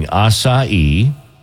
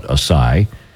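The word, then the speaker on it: açaí: A new survey by Skoda came up with the top 10 mispronounced words, including acai. You know, that's how you're supposed to say it, but a lot of people call it acai, the berries.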